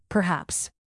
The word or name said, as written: perhaps